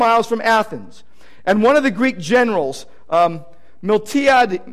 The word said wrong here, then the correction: Miltiades: Miltiades, I knew I wasn't going to pronounce that right, Miltiades,